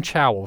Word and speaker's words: Chao: Elaine Chao, C-H-A-O, I believe. That's correct, how you pronounce her last name.